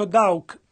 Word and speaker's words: Daugh: Not really in his name, he's a German, D-A-U-G-H, and I pronounce it Dow. – Ora, in realtà, nel suo nome, lui è tedesco, D-A-U-G-H, e io lo pronuncio Dow.